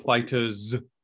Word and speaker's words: FighterZ: I mean, how do you pronounce it, man? It should be called FighterZ, right? – It's called Fighters. It's just a play.